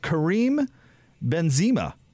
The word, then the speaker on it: Benzema: Real Madrid forward, who's also a French national team, apparently, a member, Karim Benzema.